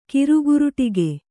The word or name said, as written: ಕಿರುಗುರುಟಿಗೆ